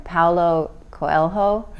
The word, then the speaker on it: Paulo Coelho: Paulo Coelho? – Yeah.